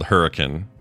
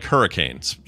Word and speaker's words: Hurricane: Here we have a little town here called Hurricane in southern Utah. – Oh, yeah. – It's spelled like hurricanes, straight up hurricane, but it's pronounced Hurricane. – Hurricane, yeah.